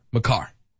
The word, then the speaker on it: Makar: Makar.